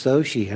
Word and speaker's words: Xochitl: They said, "It's Soshi," and I said, "Oh, I would have never got that out of that spelling."